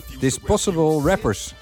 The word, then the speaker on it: Disposable Rappers: Disposable Rappers, zo spreek je dat uit.